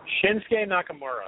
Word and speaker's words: Shinsuke Nakamura: And correct me if I'm saying this incorrectly, Shinsuke Nakamura. – Shinsuke Nakamura is correct.